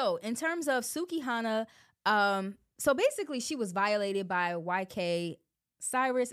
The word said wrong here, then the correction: Osiris: Is that how you say his name, bro? Osiris. YK Osiris.